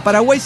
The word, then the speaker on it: Paraguay: Postdata, Paraguay significa agua para el mar, se pronuncia Paraguay y la Y significa agua y tiene una pronunciación gutural, Paraguay.